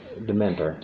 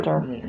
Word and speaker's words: Demeter: Dementor. – Demeter. – Demeter.